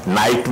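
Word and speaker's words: night: Silent लगे नाइट में जैसे GHT साइलेंट हो जाए है ऐसे वाबखत स्वरूप साइलेंट है।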